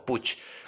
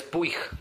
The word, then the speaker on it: Puig: ahí lo pronunciaba como Puch, pero es Puig, como el pollo Puija.